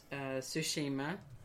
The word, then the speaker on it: Tsushima: No, but I am a little curious about Tsushima.